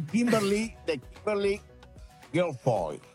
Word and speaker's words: Kimberly Guilfoyle: Vamos a hablar de Kimberly Guilfoyle.